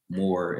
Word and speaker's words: Moore: It's at Jakari Moore, and it's spelled J-A-C-A-R-R-I, Moore, M-O-O-R-E.